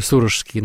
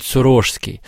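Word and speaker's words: Сурожский: Я сразу говорюсь, что по многолетней привычке я ударение ставлю в слове «Сорожский» на первый слог, хотя правильно, и вот до нашей записи Елена Львовна это подчеркнула, говорит «Сорожский». Город Сорож, епархия Сорожская, и сам владыка тоже свой титул называл «Сорожский».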